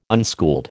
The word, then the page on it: unschooled: American English